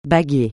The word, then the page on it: baguer: francais